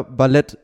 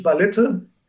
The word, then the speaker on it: Ballette: Ich habe gelernt, weil du es extra reingeschrieben hast, der Mann wird Peter Ballett nicht mit E am Ende ausgesprochen.